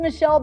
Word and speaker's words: Michele: Yeah, my name is Michele Borba. I'm a 1L girl.